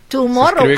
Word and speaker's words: Tomorrow: Tomorrow. Así como se pronuncia, uh-huh, así se escribe, no como en inglés.